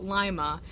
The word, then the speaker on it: Lima: And then there's Lima is the actual city, and then we call it Lima.